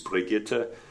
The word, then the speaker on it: Brigitte: In German, you pronounce every vowel. It's spelled like Bridget, but in German, it's Brigitte.